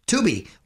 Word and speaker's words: Tubi: And being that you're on Fox, they're getting into the streaming game with Tubi. Am I pronouncing that right? Tubi. What, what you are? Yeah.